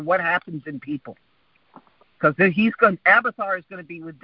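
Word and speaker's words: Abiathar: How do you say his name? Oh, Abithar. Abithar. – Yeah. – Abithar. It's Abithar.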